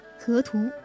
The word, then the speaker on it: 河图: 河图。不知道爱前的你们是否听说过这个有点古怪的名字？阳平声调的两个字在舌尖上一转，辗转出一段段过往，流连至今。这个名唤河图的人，我爱他的声线爱了七年。有时别人会问我河图是谁，我总是以音乐人这三个字草草带过，而常常换来对方夹杂着叹息的回应。